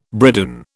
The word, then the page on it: bridoon: British English